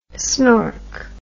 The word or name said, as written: snork